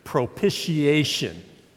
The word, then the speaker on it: propitiation: Now, I just lost some of you with a big 16-cylinder theological word or Bible word called propitiation. Say that with me, propitiation. Yeah, some of these guys from the South turned it into a one or two-syllable word. It's like three or four, all right?